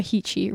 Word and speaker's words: Heechy: Sarah Heechy rhymes with beachy.